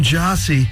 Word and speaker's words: Gyasi: We'll be playing something from the new ASC album real soon in this upcoming hour, but new from Jossie. And if you like that and you're looking it up online or you're trying to go to your record store and check it out, it's spelled G-Y-A-S-I, Jossie.